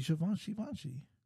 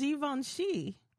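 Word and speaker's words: Givenchy: Why are you saying it like that? – Isn't that how you say it? – It's just Givanshi. – Oh, is that how you say it? – Yeah. – Givanshi. I thought it was Givanshi Vanshi. – I don't think so. I mean, I could be wrong, but I'm almost certain.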